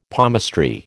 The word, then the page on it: palmistry: American English